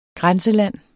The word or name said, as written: grænseland